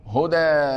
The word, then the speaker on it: هدى: لماذا أولا، أول سؤال نسأله، هدى أنت نطقت ألفا أم ياء؟ ألفا. فلماذا كتبتها ياء؟ لأنها تدل على الأصل.